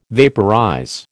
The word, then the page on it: vaporize: American English